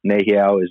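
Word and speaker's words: Nēhiyaw: And for the people, Nehiao is the proper way to say Cree. So Cree was a mislabel.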